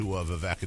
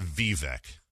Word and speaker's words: Vivek: It's not Vivek. It's Vivek.